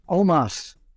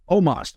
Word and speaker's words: Omos: Omos. Is that how you say his name? – Omos. – Omos.